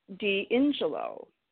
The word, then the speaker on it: D'Ingillo: My name is pronounced D'Angelo.